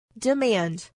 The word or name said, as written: demand